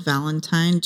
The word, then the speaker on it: Valentine: And my first name is spelled D-Y-A-N-A. And my last name is spelled Valentine, just like Valentine's Day.